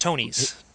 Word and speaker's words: Tonnies: Oh, and it's pronounced Tonies. – Tonies.